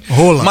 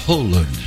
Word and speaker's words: Haaland: Então, a galera tá fazendo piada na internet, fazendo meme, porque realmente se pronuncia na Noruega assim. – Haaland. – Mas nós, brasileiros, falamos Haaland.